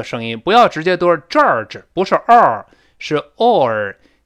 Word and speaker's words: George: 哎，首先上来这个字母 G，这里发 J J，不要读成 G 七西那个 G。那我们多次说过，在英语中没有 G 七西这样的发音，这是 J J，所以不要读成 George，一听好像像卷纸一样，那不是卷纸啊。我们大声再读一遍。 George, George，